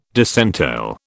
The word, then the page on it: disentail: British English